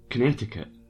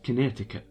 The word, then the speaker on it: Connecticut: So I wrote down Connecticut, and as I'm writing this down, it's pronounced Connecticut. Connecticut. That's right, Connecticut. But as I'm writing it down, unless I've spelt this incorrectly, it says connect. It's spelt like, if I was just to look at it and split it up, connect. A cut. Connecticut. Because it's got the word connect, then I-C-U-T.